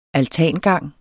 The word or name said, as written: altangang